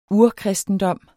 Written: urkristendom

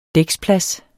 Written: dæksplads

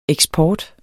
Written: EKSPORTEN

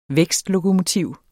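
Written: vækstlokomotiv

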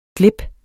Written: glip

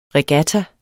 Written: regatta

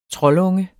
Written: troldunge

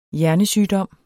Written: hjernesygdom

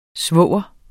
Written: svoger

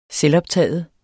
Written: selvoptaget

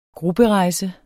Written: GRUPPEREJSER

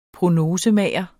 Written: prognosemager

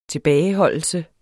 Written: tilbageholdelse

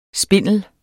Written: spindler

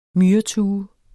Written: MYRETUEN

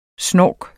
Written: snork

